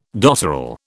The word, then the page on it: dotterel: British English